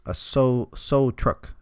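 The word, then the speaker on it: sáo trúc: It's called a so truck is how it's pronounced, but it's a small flute found in Vietnam.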